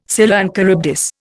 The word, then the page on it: Scylla and Charybdis: Indian